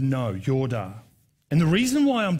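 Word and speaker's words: yoda (ידע): It's actually pronounced in the original Hebrew, yoda. Everybody say yoda, right?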